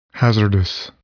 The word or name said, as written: hazardous